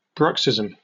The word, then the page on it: bruxism: British English